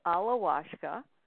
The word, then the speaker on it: Alawashka: So it's Alawashka, and I'll spell it for everybody.